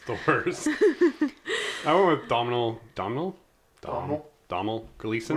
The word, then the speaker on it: Domhnall Gleeson: No, I'm pretty sure it's Domhnall Gleeson. He's said it before. It sounds like you're saying Donald, but you're not.